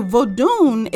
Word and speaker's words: Vodoun: So Vodun is spelled V-O-D-O-U-N, or it can also be phonetically spelled as V-O-D-U-N, and it's Vodun, right? So Vodun with an N at the end of the word, okay?